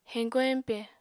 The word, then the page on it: heñko-heñpe: Apatani